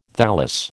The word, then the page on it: thallus: American English